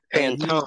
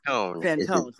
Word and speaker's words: Fantone: The guy's name is Matt Fantoni. I think that's how you say it. Yeah, Matt Fantoni. – Fantone. – The new host. – Fantone. – Sorry. Yes. So the new show is Mixed Mornings with Matt Fantone, and he is weekday mornings from 6 to 10 Eastern.